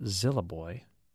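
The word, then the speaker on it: Isle du Bois: And Jim and Keller says there's a park on Lake Ray Roberts called Île du Bois, but locals pronounce it Zillaboy.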